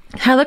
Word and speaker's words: Helle: And her name is Hella. H-E-L-L-E is how you spell it. But right. It's Hella.